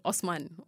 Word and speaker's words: Osman: Richard Osmond, but spelled like Osman, O-S-M-A-N.